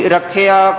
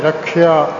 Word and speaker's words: रखिया: रखिया क्रोध हाय रखिया क्यों उच्चारण रखा नहीं रखिया क्रोध